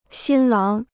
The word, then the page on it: 新郎: xīn láng